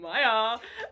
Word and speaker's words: Mya: I am Maya. You'd think it'd start with M. It does. It's M-Y-A.